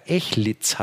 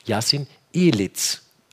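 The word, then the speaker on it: Yasin Eliz: hat er mir gesagt, würde der Name sogar Echlitz heißen und auf Deutsch so ausgesprochen, mhm. wie es für ihn ist okay ist, Yassin Elitz. Also mit Z und nicht mit S hinten.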